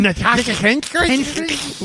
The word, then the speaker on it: Natasha Henstridge: Natasha Henstridge? – That was in my mouth.